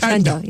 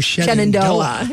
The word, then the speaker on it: Shenandoah: Yeah, Chendo. Yeah, say Chendo. – Or Shenandoah.